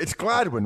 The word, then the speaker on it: Gladwyne: You live near there, right? It's Gladwyne, right? – Yeah, it's Gladwyne. – Well, did you not hear? He goes, Gladwyne. – Well, maybe I was doing my Drew Westchester impersonation. – You put the wrong emphasis on the wrong syllable.